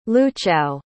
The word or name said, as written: luchow